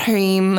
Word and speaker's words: Hrim: And today's mantra is Om Hrim, which is spelled H-R-I-M, and it's pronounced Hrim, kind of with a Hrim. And you might see it written around as H-R-E-E-M.